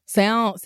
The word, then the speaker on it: salmon: Salmon. – Salmon. – Well, sometimes I look at salmon and I know full well that I'm not supposed to pronounce the L, but I want to.